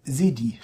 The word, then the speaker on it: Cedi: Neue ghanaische Kedi. Cedi. – Ist jetzt doch kein so schönes Wort, wie ich dachte. – Heißt das wirklich Cedi? Ja. Wie spricht man das? Ist das bei Sedi steht da.